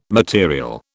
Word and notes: material: British English

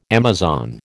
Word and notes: Amazon: American English

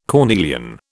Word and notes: cornelian: British English

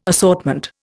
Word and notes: assortment: Indian

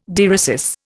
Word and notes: dieresis: Indian

